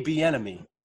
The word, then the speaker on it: Bieniemy: Eric, the enemy, Bieniemy. – That's how you say it.